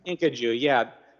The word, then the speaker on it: kinkajou: You have America being presented as this land of wonder and opportunity because the owner, the elderly owner of the titular Kinkajou, I'm not sure I'm even pronouncing it right. – It's a Kinkajou.